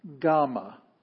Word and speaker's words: Gamma: Gamma it's a G sound, as in gone. Uh, always a hard G, never a J, but a G. It's always G.